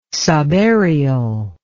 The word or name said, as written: subaerial